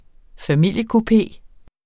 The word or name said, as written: familiekupé